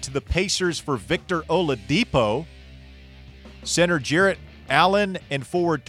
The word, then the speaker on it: Oladipo: That's how you say his name. It's Victor Oladipo.